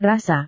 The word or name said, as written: rasa